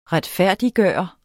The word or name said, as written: retfærdiggøre